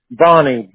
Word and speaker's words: Bonney: Bonnie, B-O-N-N-E-Y.